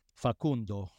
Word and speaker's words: Facundo: How do you pronounce his first name, Enzo? – Facundo Buonanotte for Brighton, owned in 35% of leagues.